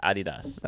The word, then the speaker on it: Adidas: It's actually pronounced Adidas.